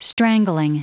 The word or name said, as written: strangling